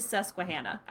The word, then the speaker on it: Susquehanna: It is Susquehanna.